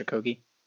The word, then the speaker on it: Okogie: They have what is it, Josh Okogie? – Oh, I only know his name because it rhymes with Hoagie.